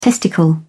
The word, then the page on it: testicle: British English